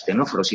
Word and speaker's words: Ctenóforos: Muy bien, pues fijaos, hasta hace muy poquito, este de tenóforos, que se estaba junto a este otro que también tiene una C delante, que se llama Nidarios, o sea, tanto los dos que tienen C que no se pronuncian, o sea, los Ctenóforos y los Nidarios,